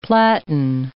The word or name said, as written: platan